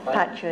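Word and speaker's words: pacchā: And P-A-C-C, long A, B-H-I, N-N-Y-A, N-N-Y-A, long A, N-A, Pacha Binyana Rasa. After. – What is that? Pancha. – Pacha is after. – Pacha or Pancha? – B. After Pacha, P-A-C-C, long A, right? – Yes.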